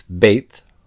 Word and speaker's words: beit: The word bayit can take an alternate form where it's beit, spelled beit, and pronounced that way.